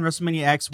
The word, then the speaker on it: WrestleMania X: I was going to say X. Is it X? – It is X. – WrestleMania 10, WrestleMania X, whatever your preferred pronunciation is.